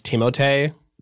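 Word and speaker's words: Timothée: Speaking of pronouncing names, though, I just want to say that I learned recently that it's Timotei. Timotei Chalamet. – Timotei Chalamet. Oh, okay. – Well, it's spelled Timotei. – And I had thought that, but I was like, no way. Because everyone calls him Timothy.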